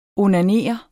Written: onanere

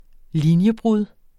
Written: linjebrud

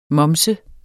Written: moms

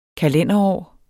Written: kalenderår

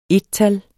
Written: ettal

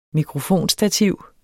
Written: mikrofonstativ

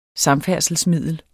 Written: samfærdselsmiddel

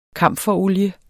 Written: kamferolie

